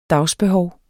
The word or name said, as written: dagsbehov